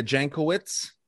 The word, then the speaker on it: Jankowicz: Yeah, dude, this the lady that's heading that, Nina Jankowicz, is how you say her name.